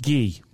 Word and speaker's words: ghee: G-double-E. Your ghee bag. – Oh, yeah. – That's pronounced ghee.